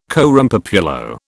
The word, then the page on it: coram populo: British English